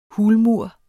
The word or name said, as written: hulmur